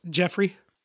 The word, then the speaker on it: Jefe Rey: Fun fact, my brother's middle name is spelled Jefe Rey. – Jefe Rey? – Which is boss and king in Spanish. But his middle name is just pronounced Jeffrey.